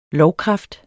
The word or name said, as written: lovkraft